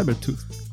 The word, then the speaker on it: Cybertooth: Eu consegui duas expansões dele, que são o Anubis e o Cybertooth. Cybertooth? Como é que é que é a pronúncia? Cybertooth. Ah, então estava certo, não precisava nem ter perguntado.